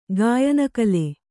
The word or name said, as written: ಗಾಯನಕಲೆ